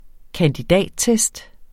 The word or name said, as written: kandidattest